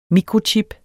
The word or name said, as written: mikrochip